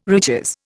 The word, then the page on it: riches: Indian